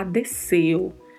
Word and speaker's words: deseo: Não, é pronúncia de S mesmo, então vamos falar desceu, desceu.